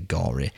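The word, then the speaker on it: Gorre: Dean Gore, or Dean Gore, it's pronounced G. It's spelled G-O-R-R-E. I'm going to say Gore.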